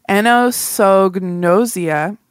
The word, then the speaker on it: anosognosia: I'm gonna pronounce it wrong. Anosognosia. – Sounds good to me.